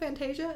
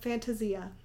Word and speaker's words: Fantasia: And when I went, I was like, is this called Fantasia? And they were like, no, it's called Fantasia.